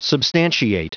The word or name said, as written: substantiate